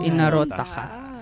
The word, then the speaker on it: Inarotaka: Wow, Inarotaka. – Diba? Did I say that right? Yeah. – Yes!